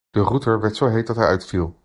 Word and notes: heet: Dutch

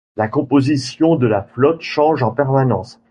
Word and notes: permanence: French

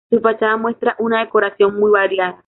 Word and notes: fachada: spanish

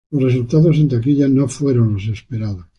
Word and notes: taquilla: spanisch

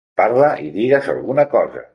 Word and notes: digues: Catalan

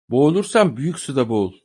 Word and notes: suda: turkce

